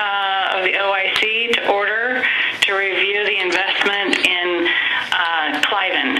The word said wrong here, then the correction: Cinven: Did I pronounce that properly? – Cinven. – Okay, close enough.